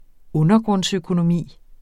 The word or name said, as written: undergrundsøkonomi